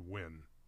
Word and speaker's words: Nguyen: Oh, that's pronounced Wynn, the first name. – Wynn? – Yes, Wynn Van Thieu.